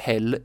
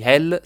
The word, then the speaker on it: Hel: Il numero 666 è associato al diavolo e il nome della città si pronuncia come la parola inglese Hell, che significa inferno.